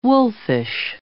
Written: wolfish

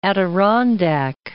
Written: Adirondack Mountains

